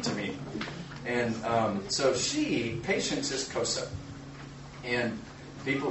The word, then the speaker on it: Xhosa: But the two big languages over there besides English is Afrikaans, which is like a Dutch, or Xhosa, which is actually pronounced Xhosa.